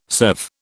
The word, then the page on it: serve: British English